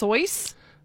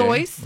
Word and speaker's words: Seuss: I guess his real name is pronounced Soyce. Like Joyce, okay. – Soyce.